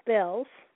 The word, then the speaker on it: Spelles: The song is called Bird in a Cage by, I'll spell it in case my pronunciation is not correct. It's called Spells, but it's spelled S-P-E-L-L-E-S.